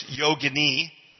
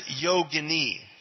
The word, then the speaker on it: yogini: Mispronounced all the time, right? As yogini, right? Do you pronounce it as yogini? No, not anymore. No, it's yogini, okay? If it rhymes with fettuccine, linguine, it's probably an Italian preparation. Okay? But it is yogini. Yogini, okay? So, yogini. But